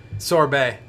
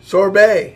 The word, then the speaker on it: Sorbet: I mean, it's actually even in the Sobers. – Sorbet. – Sorbet. – Yeah, the tea is silent.